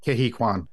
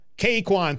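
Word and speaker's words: Ke Huy Quan: How do you say his name? – Kehi Kwan. – Kehi Kwan, thank you.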